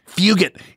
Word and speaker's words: Fugit: Fugit. It rhymes with nougat.